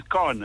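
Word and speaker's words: K'naan: No, the artist is Khan, Africa. – I don't know, actually, I don't know how to, because it's spelled K apostrophe N-A-A-N. But thank you for teaching me how to pronounce his name, actually.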